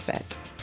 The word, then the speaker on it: fête: It could be Dead Feet, but in French, it's pronounced fête.